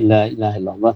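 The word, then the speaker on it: La ilaha illallah (لا إله إلا الله): Lamnya di depan harus dibaca panjang.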